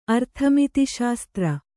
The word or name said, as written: ಅರ್ಥಮಿತಿಶಾಸ್ತ್ರ